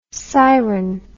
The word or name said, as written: seiren